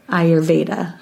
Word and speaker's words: Ayurveda: Think of like A-Y is the first letter, so Ayurveda. It's like I.